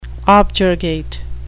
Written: objurgate

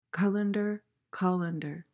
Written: colander

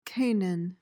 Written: canaan

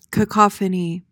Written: cacophony

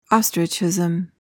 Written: ostrichism

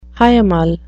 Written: hiemal

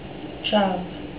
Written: chav

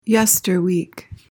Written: yesterweek

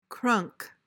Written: crunk

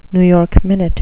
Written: new york minute

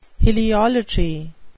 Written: heliolatry

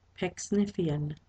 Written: pecksniffian

